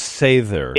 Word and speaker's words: Sather: And by the way, it's long A, isn't it? It's Sather. – It is. It should be Sather.